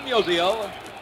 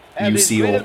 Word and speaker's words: Musial: In the last half of the 12th inning, Stan Musial. – Finally, someone who can pronounce his name right. – The first pitch. – Not musical. – Musinex. Musial.